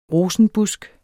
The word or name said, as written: rosenbusk